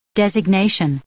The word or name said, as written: designation